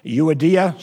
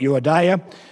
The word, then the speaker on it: Euodia: Now, I never did know how to pronounce these two people, okay? Euodia, some call it Euodia, some call it Syntyche, some call it Syntyche. One preacher couldn't pronounce them. He called them Odious and Stinky.